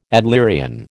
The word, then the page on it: Adlerian: American English